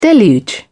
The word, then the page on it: deluge: British English